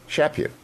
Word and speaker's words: Chaput: And I now learn that I finally have got it right, because Dwayne did the thing for the audio dyslexic, which is to spell it phonetically. So it's S-H-A-P-U-T. So, Chaput, I don't know why you didn't do that 20 years ago.